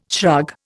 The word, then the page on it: shrug: Indian